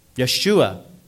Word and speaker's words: Yeshua: Yeshua is how the Jews pronounce it.